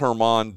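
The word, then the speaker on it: Germán: And then talking about, like, and again, nothing against German, which, by the way, just for folks, if you're thinking it's spelled German, it's spelled German, actually. But it, right. – But it's pronounced German, Domingo German.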